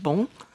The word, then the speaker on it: Beaune: Now, Beaune is, I guess in France, sometimes it's pronounced Bon, It's a medieval town with walled ramparts, etc.